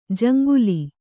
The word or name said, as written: ಜಂಗಲಿ